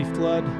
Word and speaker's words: Flued: But my name is Bobby Flood. That's spelled F-L-U-E-D, by the way, not like the water.